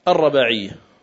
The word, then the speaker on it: الرباعية: يقول أنس رضي الله تعالى عنه وأرضاه: شج النبي صلى الله عليه وسلم يوم أحد وكسرت رباعيته، والرباعية بفتح الراء وتخفيف الباء، تخفيف الباء والياء، الرباعية هكذا تنطق، وكسرت رباعيته. والرباعية هي السن التي تلي الثنيتين، لأن السن الأولى تسمى الثنية ثم بعدها الرباعية، فكسرت صلى الله عليه وسلم رباعيته.